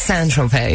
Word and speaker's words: Saint-Tropez: Well, because in French, Saint is pronounced Saint. – Saint. Saint. Saint. Saint. – Saint. Saint. – Saint. Like 50 Cent? – Saint. – So it's not even Saint? – No, it's Saint-Tropez, yeah.